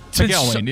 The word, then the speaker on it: McElwain: Jim McElwain, am I saying that right? – McElwain, yeah.